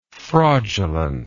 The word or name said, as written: fraudulently